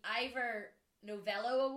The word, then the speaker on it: Ivor Novello: Well, he won, and I'm not going to be able to say this right, the Ivor Novello Award. Is that how you say that? – Yes.